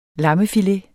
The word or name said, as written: lammefilet